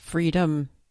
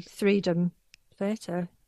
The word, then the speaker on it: Threedumb: I'd say Threedom Theatre. Yeah. Because it's written Threedom. – Freedom. – No. – Threedom. So I did read their website and it says you're supposed to say it freedom.